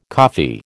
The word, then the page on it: coffee: American English